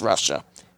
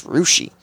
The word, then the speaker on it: Russia: Yes, it's spelled Russia, but it's not pronounced Russia. It's Rushi.